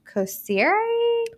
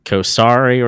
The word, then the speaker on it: Kosiri: The thing is, most people pronounce it wrong. They like Kosari or something. They'll say all these different names. Usually people don't get it on the first try, and you did.